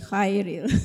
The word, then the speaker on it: Khairil: I've always been pronouncing his name as Khairil and it should be Khairil.